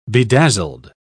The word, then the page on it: bedazzled: British English